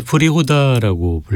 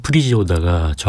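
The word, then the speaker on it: Příhoda: 한때 프리호다라고 불렸는데요. 프리지호다가 정확한 발음이고요.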